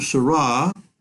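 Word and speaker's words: Sarah: So let's go on to what Cardinal Seurat, rhymes with hurrah, as I say, says about the liturgy in the context of the crisis of the Church.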